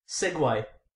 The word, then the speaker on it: segue: You know, one of mine was Segway. – Go on. – S-E-G-U-E.